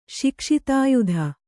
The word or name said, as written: ಶಿಕ್ಷಿತಾಯುಧ